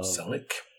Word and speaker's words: Selick: Eh, men det var lite om eh, Henry, jag vet inte om man ska uttala det, Selig? – Eller Selick? Eller... – Selleck. Men det var han.